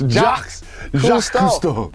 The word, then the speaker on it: Jacques Cousteau: Boy, first of all, say his name again. – Jacques Cousteau. – You act like it's like Ja Morant. – It's Jacques Cousteau.